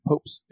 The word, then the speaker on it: Poapst: Popes. P-O-A-P-S-T. Popes. They always pronounced it Popes.